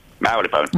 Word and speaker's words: Marylebone: MARYLEBONE. How would you say that? – Marylebone.